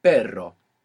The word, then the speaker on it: perro: So initial R or two R's together inside the word is gonna pronounce this double R. So the word for but in Spanish is pero. – Pero. – P-E-R-O. And the word for a dog is perro. P-E-R-R-O.